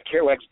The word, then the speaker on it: Kerouac's: How do you say that? Kerouac's? – Yeah, Kerouac's Dog.